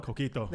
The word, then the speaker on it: Coquito: Say it right like you know it, man. – Coquito. Come Coquito. On. You've had it before. – Coquito. – There we go. You sound like a pro.